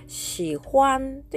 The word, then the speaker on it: 喜欢: えー、これで 2 つ合わせて、シホアンというふうに発音します。最初の文字はシということで、えー、発音記号が XI の第三声、低く沈む音。